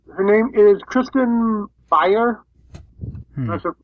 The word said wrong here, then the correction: Bauer: B-A-U-E-R. Blonde, fairly attractive. – B-A-U-E-R is probably Bauer. – Bauer? Okay. Well, Kristen Bauer.